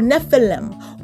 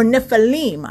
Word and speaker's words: Nephilim: Well, that term giant is a term that's now, and they said actually it's translatable to the original Hebrew, which is a term called Nephilim or Nephilim. I've heard people pronounce it either way.